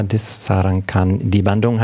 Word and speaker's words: Disarankan di Bandung: Disarankan. Die Bandung heißt dieser Track.